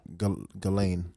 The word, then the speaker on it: Ghislaine: The first day, what, how do you pronounce her first name? Yeah, Ghislaine. – Ghislaine Maxwell. – The S is silent.